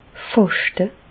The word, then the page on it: fàschte: Bas-Rhin; Reichshoffen